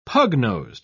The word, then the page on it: pug-nosed: British English